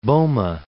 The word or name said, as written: Boma